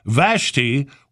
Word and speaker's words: Vashti: Most local people pronounce Vashti with a short A like ash and a long like tie, with only slightly more emphasis on the first syllable than the second. If you must use Vashti in your story and try to say it like the internet wants you to pronounce the name of the Queen of Persia from the Book of Esther in the Old Testament, you will sound like an idiot to your audience in Alexander County. And I know how much your cast tries to avoid that.